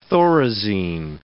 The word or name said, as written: Thorazine